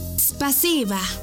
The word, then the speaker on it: Спасибо: En ruso, gracias se dice спасибо. ¿Cómo? Спасибо. Una vez más. Спасибо. Y se escribe спасибо. S P A S I B A. Спасибо.